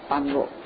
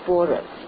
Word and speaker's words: 般若: 所以不念成般若，是念成波若，也就是佛法所说的最高的智慧的意思。